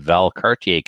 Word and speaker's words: Valcartier: Sunday, February 24th, CRPS will be having its first event of 2019 in Valcartier, Quebec. See, he's not the only one who can pronounce it, Kelly. You actually took the trouble to congratulate him in the show. Wow, you pronounced that correct.